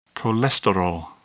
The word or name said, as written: cholesterol